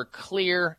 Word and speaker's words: Xlear: I would walk into a health food store and purchase something called Clear Nasal Wash, or Clear, and it's spelled X-L-E-A-R. The X stands for xylitol.